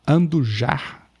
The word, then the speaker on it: Andujar: Tem a, outro nome também importante, que é a Cláudia Andula, Andujar, desculpe a pronúncia.